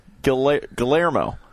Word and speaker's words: Guillermo: I mean, you say it the way it's spelled. It's like Guillermo. So to me, that's Guillermo. – Uh-huh. – And it's like the L's are silent or something.